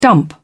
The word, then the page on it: dump: British English